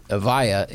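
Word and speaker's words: Evija: But the Avaya is Avaya. So yeah. It's like, that's a hard I.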